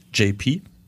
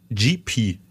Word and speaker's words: JP: Es heißt JP, nicht GP steht hier.